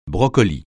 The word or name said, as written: brocoli